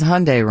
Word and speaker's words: Hyundai: Why is that, you know, a Hyundai ad? But it was actually to sell their app or something. – Although everybody says Hyundai wrong. It's pronounced Hyundai like Sunday.